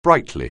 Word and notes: brightly: anglais

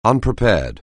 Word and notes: unprepared: anglais

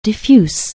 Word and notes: diffuse: anglais

